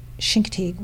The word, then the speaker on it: Chincoteague: It's actually a story of my sister and I who went down to Chincoteague, where you pronounce Chincoteague with, like, Chicago.